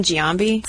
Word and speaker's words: Giambi: Let's see, Jason Giambi, probably saying that wrong. Jason Giambi. – It's totally cool.